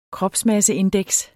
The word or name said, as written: kropsmasseindeks